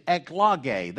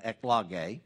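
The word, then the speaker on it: eklogē (ἐκλογή): And the word election, when used in the New Testament, is eklogē. That's the Greek pronunciation. That's the phonetic spelling, the eklogē.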